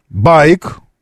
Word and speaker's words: BAIC: Э, байк. Ударение на первый слог. То, что пишется как B-A-I-C, байк.